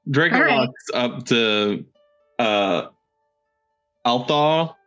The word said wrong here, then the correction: Altoth: I can't. Please tell me how to pronounce that correctly. – Altoth. – Okay, I'm just making sure I got it right.